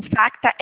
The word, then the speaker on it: Facta: Como que é esse fax? Como que se escreve fax? – O Facta é F de faca, A, C, T, A.